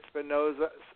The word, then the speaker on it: Spinozza: Did Luther Vandross sing Superstar along with Diva Gray and David Lasley in 1978 on the guitarist David Spinozza? Is that how you pronounce his name? Spinozza? The album Spinozza? – Yeah.